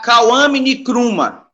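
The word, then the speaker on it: Kwame Nkrumah: Pode, pode pronunciar Kawame Nikruma ou Nikruma. – Nikruma ou Nikruma, Kawame Nikruma. Kawame é sábado, e Nikruma mesmo é, é, o nome dele. Então, Kawame é quem nasce no sábado.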